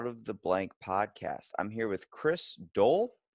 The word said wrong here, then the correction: Doelle: It's pronounced Daily, actually. – Daily. That's a weird way of spelling it. – Yeah, it's German.